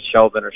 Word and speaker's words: Chauvin: the pronunciation is Chauvin. – Chauvin is what I'm getting.